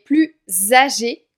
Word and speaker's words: plus: Alors ici, on va prononcer le S de plus comme un Z, car il y a le mot âgé qui suit, qui commence par une voyelle. Il est plus âgé que son frère.